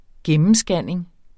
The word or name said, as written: gennemscanning